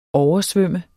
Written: oversvømme

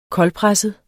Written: koldpresset

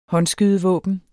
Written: håndskydevåben

